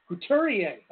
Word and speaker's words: Couturier: How'd you pronounce that name? – Couturier, okay.